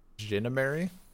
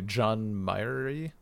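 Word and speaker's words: Jeannemary: I would definitely have pronounced this person's name as like, or I did pronounce this person's name as like, Jinamary, but it's apparently John Myrie.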